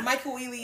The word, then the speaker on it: Michael Ealy: Am I saying his name right? Michael Ealy? – Michael Ealy, yes.